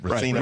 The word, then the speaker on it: racino: Is that how you say it? Racino.